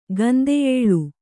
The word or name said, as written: ಗಂದೆಯೇಳು